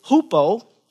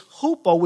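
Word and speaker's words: hupo (ὑπό): The word "under" here in the Greek is hupo. We spell it hypo, H-Y-P-O. It's hupo, which it's a military term.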